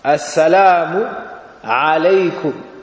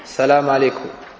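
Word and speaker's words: Assalamu alaykum (السلام عليكم): Donc, tu dis Assalamu avec Alif Lam. Assalamu alaykum. Tu dis pas Salam alaykum, comme ça. Aussi, il y a un Aïn dans le salam. Assalamu alaykum. Alaykum. Pas Salam alaykum. Non, alaykum.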